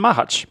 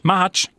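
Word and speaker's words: Macháč: Och framförallt så var det, och du rättar mig med uttal här, Thomas Mahac. – Ja, men Mahac. – Mahac, bra. Toppar han korta shortsligan där på Franska Öppna.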